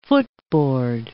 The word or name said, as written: footboard